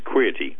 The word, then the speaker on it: Queerty: According to, I guess it's pronounced Queerty.